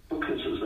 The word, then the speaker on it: Booknesses: So there's an exhibition showcasing diverse book arts practices by South African artists, as well as the much anticipated exhibition called, do I say this right, Booknesses? – Booknesses, that's correct.